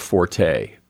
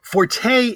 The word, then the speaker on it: forte: Forte in music is F-O-R-T-E. And it's correctly pronounced forte. In, like, when you're talking about someone's strength or ability, it's again spelled F-O-R-T-E. And technically, it should be pronounced fort. Comes from the French. It should be pronounced fort, not forte.